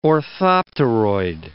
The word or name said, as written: orthopteroid